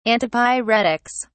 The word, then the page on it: antipyretics: British English